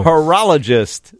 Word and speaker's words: horologist: So horologist. Did I say that right? – You did.